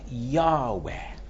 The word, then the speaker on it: Yahweh: They don't put in the vowels between the letters. It would be pronounced something like Yahweh.